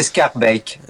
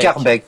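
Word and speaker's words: Schaerbeek: Alors, c'est Scarbeck. – Scarbeck. – C'est Scarbeck, on dit.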